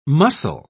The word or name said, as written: mussel